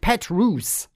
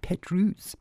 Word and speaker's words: Petrus: Somebody's angry texting in to say it's pronounced Petrus. – Oh. – Okay, sorry. – Sorry. – Mm-hmm. Petrus.